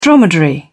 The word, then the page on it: dromedary: British English